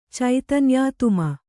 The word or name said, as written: ಚೈತನ್ಯಾತ್ಮ